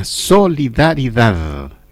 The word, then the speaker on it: solidaridad: Y en memoria de él es que tenemos este Día Nacional de la Solidaridad. Bien dicha la palabra, ¿eh? No como el checopete. No, así no. Bien pronunciadito, solidaridad, que tiene una D final, ¿ya?